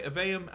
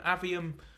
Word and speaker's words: Aveum: Aveum, Avium, doesn't really matter whichever way.